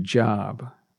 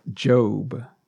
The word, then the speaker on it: Job: And it's interesting, Job's name is spelled J-O-B, which is the same spelling for the word job. But as far as a man's name, it's pronounced Job, J-O-B.